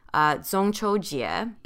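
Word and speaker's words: Zhongqiu Jie (中秋节): In Mandarin, the Mid-Autumn Festival is pronounced Zhongqiu Jie.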